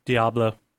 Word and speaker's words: Diablo: How do you say Diablo? – Diablo. – Yeah, that's the way you should say it. Or something roughly like that. – Well, no, I just say that because, you know, when I took Spanish, that's how you pronounced it.